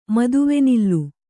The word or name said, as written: ಮದುವೆನಿಲ್ಲು